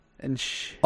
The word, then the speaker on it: Enschede: It's a city called Enschede. – Mm-hmm. That was it? – Yeah. – Enschede? – E-N-S-C-H-E-D-E. – Enschede. Oh, Enschede.